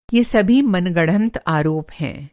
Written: मनगढ़ंत

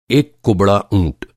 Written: ऊँट

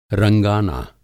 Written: रंगाना

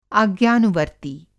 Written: आज्ञानुवर्त्ती

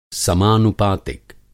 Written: समानुपातिक

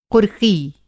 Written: कुर्की

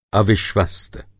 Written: अविश्वस्त

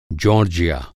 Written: जॉर्जिया

